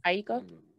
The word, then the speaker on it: Aiko: How you say her name? – Aiko.